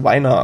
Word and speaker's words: Weiner: Er schreibt sich so, dass man hätte hoffen können, dass er von Amerikanern Wiener ausgesprochen wird, aber ich habe extra nachgeguckt. Talkshow-Hosts und so haben ihn immer als Weiner angekündigt.